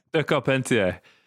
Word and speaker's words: De Carpentier: De Carpentier. But you get so many people saying De Carpenter or loads of crap, just like all sorts of...